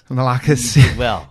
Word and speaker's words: Malarkus: Malarkus. – Malarkus with Attitude. – But that's the correct pronunciation? – Yeah, that's it. You did it perfectly.